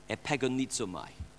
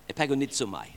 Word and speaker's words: epagonizomai: To contend in the Greek is the word epagonizomai. Can you say that? Epagonizomai. It took me about 8 goes to get it right this morning. Epagonizomai. That's how it's pronounced, because you can act.